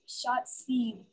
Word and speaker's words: Shotzi: Yelling Yahtzee, but Shotzi.